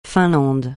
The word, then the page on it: Finlande: francais